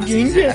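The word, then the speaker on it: Gengar: ¿Por qué Jengar? – Así se pronuncia.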